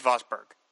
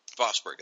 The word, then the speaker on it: Vosburg: So I was very excited to come into this and see what Mike Vostog, or how do you say his name? – Mike Vosburg. – Bossberg. That's right.